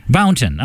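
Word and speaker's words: Bouton: It's Jim Blount, isn't it? Is that how you say it? – Bounton. – I'm sorry, Bounton.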